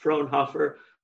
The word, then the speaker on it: Frohnhoefer: And for the record, my name is pronounced Frohnhoefer, but I answer fine just to Ray.